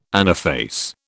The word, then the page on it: anaphase: British English